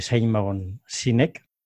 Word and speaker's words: Simon Sinek: ¿Nos puedes explicar qué es el círculo dorado de Simon Sinek? Lo he pronunciado bien, ¿no? – Lo has pronunciado muy bien.